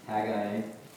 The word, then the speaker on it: Haggai: Haggai also is an acceptable pronunciation.